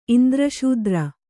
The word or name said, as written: ಇಂದ್ರಶೂದ್ರ